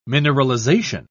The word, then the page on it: mineralization: British English